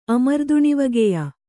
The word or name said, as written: ಅಮರ್ದುಣಿವಗೆಯ